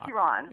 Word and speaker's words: Iran: And the first thing I realized as we were getting ready for the show is that Americans pronounce the name of that country at least three different ways. – It's Iran. – Yeah, yeah. I know. It's Iran, but you hear Iran, Iran, Iran.